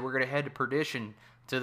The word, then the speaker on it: Perdition: I don't know why I keep saying partition, and I'm like, partition? – Right, awesome. You're like, why did I say that?